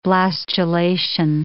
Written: blastulation

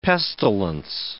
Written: pestilence

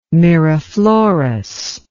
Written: Miraflores

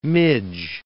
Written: midge

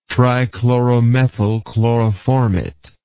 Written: trichloromethyl chloroformate

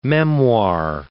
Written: memoir

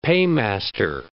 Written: paymaster

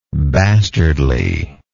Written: bastardly

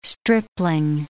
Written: stripling

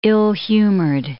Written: ill-humored

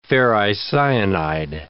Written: ferricyanide

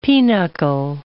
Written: pinochle

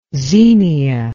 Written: zenia

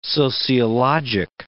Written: sociologic

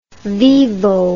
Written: vivo

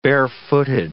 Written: barefooted

